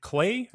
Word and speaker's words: Klei: I think that, you know, part of the grabbiness of Griftlands very clearly is that it seems like, is it clay? Is that how you say it? – Yeah.